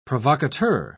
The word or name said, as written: provocateur